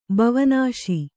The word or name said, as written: ಬವನಾಸಿ